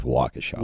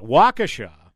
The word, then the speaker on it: Waukesha: 48 hours later, this incident happens in Waukesha, Wisconsin. This was the initial AP report. Yeah, go ahead. – Just want to clarify, Waukesha. I've got family over there. It is Waukesha.